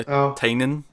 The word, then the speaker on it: Tinian: Well, I'd probably be more excited for Tinian at this point, although we learned today apparently we're pronouncing Tinian wrong. It's apparently Tinian.